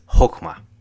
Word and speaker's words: chokmah: The Hebrew word for wisdom used throughout this book is chokmah. If it doesn't sound like you're hawking a loogie, then you're not saying it right.